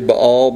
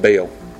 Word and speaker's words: Baal: I'm making a point to say Baal because that's the way it's supposed to be pronounced, but I was raised saying Baal. So I might slip up and say Baal because it's just easier for my hillbilly mouth to say that.